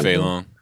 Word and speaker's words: Fei Long: And Fei Long, is it Fei Long? – Yeah, Fei Long.